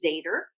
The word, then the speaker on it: Zader: So I, you know, my married name was Zader, which Z-A-D-E-R, which was a very cool name. My maiden name was Camp, K-A-M-P, very German, with nothing wrong with it.